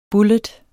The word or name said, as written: bullet